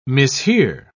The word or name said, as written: mishear